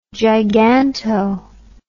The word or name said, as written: giganto